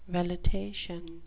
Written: velitation

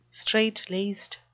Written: straitlaced

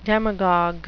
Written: demagogue

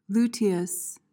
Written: luteous

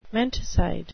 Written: menticide

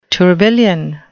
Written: tourbillion

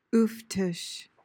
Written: ooftish

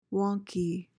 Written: wonky

